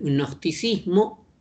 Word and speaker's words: gnosticismo: Cuando digo transversal, quiere decir que este pensamiento, que es el gnosticismo, se escribe con G, pero es una G que apenas se pronuncia, el gnosticismo, es una orientación del pensamiento que atraviesa al pensamiento cristiano, el pensamiento judío y también al pensamiento filosófico contemporáneo.